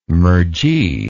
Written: mergee

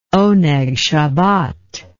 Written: oneg shabbat

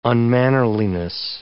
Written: unmannerliness

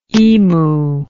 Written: imu

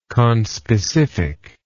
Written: conspecific

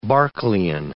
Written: Berkeleyan

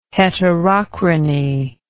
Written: heterochrony

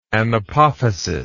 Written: anapophysial

